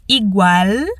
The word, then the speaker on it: igual: Quero que você preste atenção à pronúncia do L ao final nas palavras igual e el. É mais exagerado mesmo, sem fazer som de U.